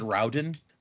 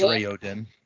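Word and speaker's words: Raoden: In my head, I always said Rowden, but apparently it is Rayodin. – Is it like Rayodin all at once, or is it Rayodin? – This has it as capital Ray-O-H-D-E-N. – Rayodin. – Three syllables.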